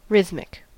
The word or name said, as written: rhythmic